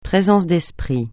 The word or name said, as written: présence d'esprit